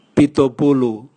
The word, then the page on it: bitobulu: Raga